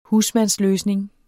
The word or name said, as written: husmandsløsning